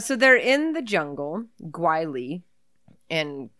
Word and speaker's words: Guile: How do you spell it? – G-U-L-E. – Oh. – G-U-I-L-E, sorry. – Gwheel. – Wheel. – It's really bad Guile.